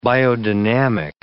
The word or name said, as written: biodynamic